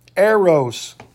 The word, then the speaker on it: eros: And then there is another one that's called eros. Eros, pronounced that way, is spelled E-R-O-S.